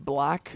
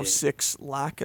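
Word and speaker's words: 6LACK: And Black, so Six Lack, as some people mistakenly pronounce it.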